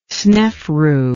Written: Snefru

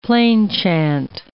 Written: plainchant

